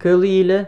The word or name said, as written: CAOL ILA